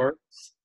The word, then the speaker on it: Wirfs: how do you say it? Werfs? Werf. – Yeah. Werfs.